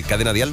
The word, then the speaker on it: Cadena Dial: Hay que ir al Twitter de Cadena Dial, ¿lo he dicho bien? – ¿Sale? Lo has dicho genial.